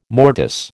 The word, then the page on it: mortice: American English